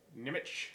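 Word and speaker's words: Nemec: Nimic. – It's N-E-M-E-C.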